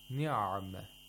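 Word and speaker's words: نِعْمَة: And if you say ni'ma, you're spending too much time on the ayin. It should be in between ni'ma, ni'ma.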